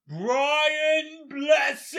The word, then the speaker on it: Brian Blessed: Brian Blessed. – You said it wrong. – I can't say it like him. – It's Brian Blessed!